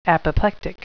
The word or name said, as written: apoplectic